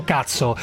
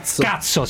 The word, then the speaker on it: Kutso: Allora, si scrive KUTSO, che non è TSO, anche se ogni tanto sarebbero da TSO per quello che fanno, cioè trattamento sanitario obbligatorio, sì. Ma si chiama, io ho capito come si pronuncia, cuzzo o cazzo? – Cazzo, si pronuncia, sì. Cazzo, dunque, sì.